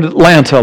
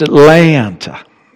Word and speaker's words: Atlanta: We're slow learners, but we understood that we don't say the word Atlanta like you say the word Atlanta. We said the word Atlanta, Atlanta. But we found out it should be pronounced Atlanta. Atlanta. You hold that second.